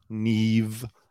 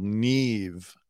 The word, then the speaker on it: Nev: This person said they got into a fight with the main guy, Neve. Not Nev, even though it's spelled N-E-V, and Nev Campbell is pronounced Nev. This guy insists on being called Neve, the host of Catfish.